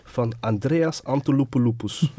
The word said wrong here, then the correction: Antonopoulos: We moeten zijn naam correct uitspreken, because he's a legend. – Antolopoulos. – Andreas Antonopoulos.